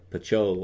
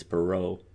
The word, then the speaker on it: Pichot: Marcel Pichot was, and that's how you say it. Some people might think it's Perot, but that's the French. So, what's a Joe Paquette from Quebec? Do you hear me? That's the French I took.